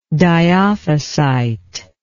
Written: dyophysitical

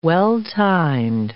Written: well-timed

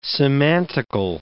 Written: semantical

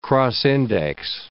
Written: cross-index

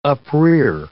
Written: uprear

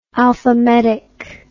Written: alphametic

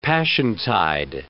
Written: Passiontide

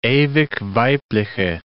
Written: Ewig-Weibliche

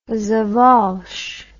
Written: sawallisch

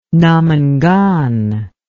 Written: Namangan

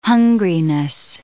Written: hungriness

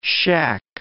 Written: shack

